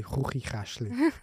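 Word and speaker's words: Chuchichäschtli: I said chachi chushli, but it's actually chuchi chashli.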